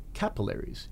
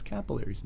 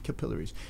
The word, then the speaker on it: capillaries: It's the little tiny blood vessels in your at the ends of your veins and it's pronounced capillaries in the United States. And when I say that to Canadians, they go, "What? No." I go, "Yeah, it's capillaries, not capillaries."